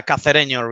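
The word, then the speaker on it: Cacereño: A game against Casareño Is it Casareño or is it, am I even pronouncing that correctly? – Yeah, Casareño, really. Yeah, it's pretty much the same.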